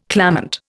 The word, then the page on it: clamant: Indian